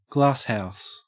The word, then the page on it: glasshouse: Australian English